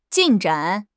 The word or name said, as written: 进展